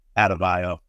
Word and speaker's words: Adebayo: Bam, Abadeo, Adebayo. You guys thought I didn't know how to pronounce that.